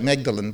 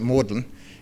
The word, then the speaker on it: Magdalen: It looks like Magdalen, but they pronounce it Magdalen in Oxford University.